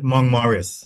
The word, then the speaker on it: Mount Moritz: Mount Moritz, but we pronounce it Mount Morris.